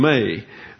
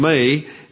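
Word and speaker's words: may (μή): Now he introduces the antagonist with a negative conjunction, may. We will see this in our study later. That's M-E, that's an eta, so it's pronounced like a long English A. And so we have the conjunction may, and it's translated lest, often used after an expression denoting fear or apprehension.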